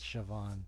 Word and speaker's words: Siobhan: Yes, it's Siobhan, but it's spelled Siobhan.